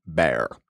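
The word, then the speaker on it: Bexar: I go back to Behar County, or as the locals say, Bear.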